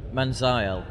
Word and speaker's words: Manziel: Jerry Jones is the greatest GM in football, and who else would do a better job of making Johnny Manziel, or Manziel, how do you pronounce it? – I don't know. – No, you specifically, not general. Most people translate Johnny Manziel. – Manziel. – Fine, good.